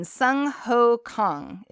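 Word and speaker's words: Jung Ho Kang: I'm not good with my Korean. Sung Ho Kong. Is that right? – That's correct.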